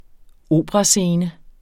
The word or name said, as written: operascene